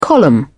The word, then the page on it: column: British English